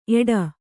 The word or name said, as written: ಏಡ